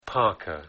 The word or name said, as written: parka